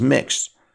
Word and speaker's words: Mx: Do you think we will ever adopt a gender-neutral title, such as MX period, which is pronounced mixed,